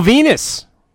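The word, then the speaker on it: Venis: All right, we are back from break, and we get a vignette for Val Venus, rhymes with penis.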